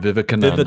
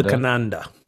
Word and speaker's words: Vivekananda: Vivekananda. Vivekananda. – Vivekananda, a Hebrew, a Hindu mystic.